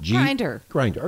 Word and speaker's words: Grindr: Grindr.